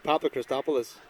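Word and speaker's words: Papachristopoulos: This is the man with the Greek name, plus for Owen Rua, Costa Pakrikopoulos. Is that his name? I think I got it right. Hold on, we're going to get it right. This is the Derry pronunciation. – Papa Christopoulos.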